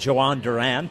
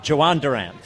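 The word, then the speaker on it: Jhoan Duran: The big trade yesterday, Phils add Joan Duran, and I believe the J is pronounced like a J. – Between him and Jahan Dotson, I'm tired of this. – I listened to him say it. – Make up your mind. – I listened to him say it like 30 times, and it was Joan Duran.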